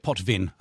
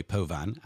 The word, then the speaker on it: Potvin: Her name is Roxanne Potvin, P-O-T-V-I-N. I suppose if you wanted, you'd call it Potvin, but I think it's going to be pronounced the French way, Potvin.